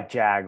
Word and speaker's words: Jag: I go by Jag all the time because a lot of people can't pronounce it.